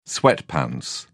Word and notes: sweatpants: British English

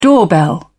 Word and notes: doorbell: British English

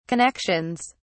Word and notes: connections: British English

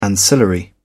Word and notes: ancillary: British English

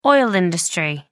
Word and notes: oil industry: American English

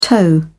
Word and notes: tow: British English

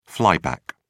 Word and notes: flyback: British English

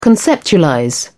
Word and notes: conceptualize: British English

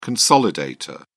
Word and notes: consolidator: British English

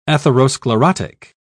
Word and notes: atherosclerotic: British English

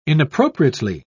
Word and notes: inappropriately: British English

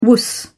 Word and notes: wuss: British English

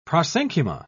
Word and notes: prosenchyma: British English

